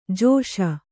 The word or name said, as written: ಜೈಸು